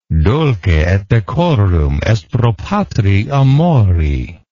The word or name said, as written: dulce et decorum est pro patria mori